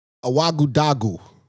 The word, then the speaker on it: Ouagadougou: Ouagadougou.